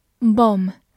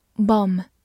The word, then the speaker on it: bomb: Bomb, b-o-m-b, bomb，字母 o 发短音 o。末尾的 b 不发音，bomb，美式发音口型比较大，bomb 也是可以的。